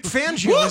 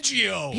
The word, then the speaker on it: Fangio: Vic Fangio. He pronounces his name wrong. It's the only problem I have. Isn't it Fangio? Why is it Fangio? – Because he's in America.